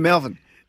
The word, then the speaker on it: Malvern: Let's go to Malvern. I think that's how you say it. Malvern. Malvern. That's how you say it properly. – You got it right.